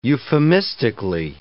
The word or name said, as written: euphemistically